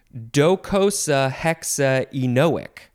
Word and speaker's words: docosahexaenoic: Docosahexaenoic. It's really spelled like it sounds.